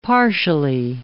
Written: partially

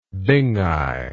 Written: binghi

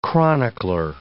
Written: chronicler

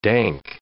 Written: dank

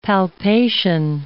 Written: palpation

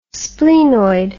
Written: splenoid

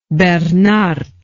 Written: beernaert

